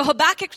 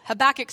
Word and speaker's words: Habakkuk: So Habakkuk chapter 3, Habakkuk sounds like a sneeze.